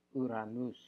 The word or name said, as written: ouranous